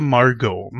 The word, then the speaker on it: Margot: Ja, aber ich glaube, sie spricht sich selbst Margot. Ja, dann, dann sagen wir Margot.